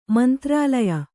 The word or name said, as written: ಮಂತ್ರಾಲಯ